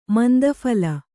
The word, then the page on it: ಮಂದಫಲ: manda phala